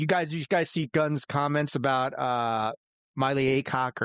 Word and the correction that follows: Millie Alcock: Did I pronounce it right? – Millie Alcock. – Yeah. I got that all wrong.